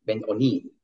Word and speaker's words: Ben-Oni: Ben-Oni, on prononce.